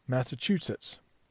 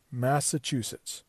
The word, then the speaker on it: Massachusetts: Think of it like this: Mass, M-A-S-S, just like an A, chew, like you do to your food, C-H-E-W, and then sits, to like sit down, Mass, chew, sits, Massachusetts. Massachusetts. Do not look at the way it's spelled. Don't try to read it, because that will completely mess you up.